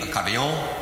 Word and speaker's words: Acadien: Now, in French, you pronounce the word Acadian, Acadien, or Acadien, depending on what kind of Acadien, Acadien you may be. In the South of Louisiana, Acadien or Acadien, being a true southerner, he just shortened it to Cajun, Cajun, Cajun. In English, you pronounce that Cajun.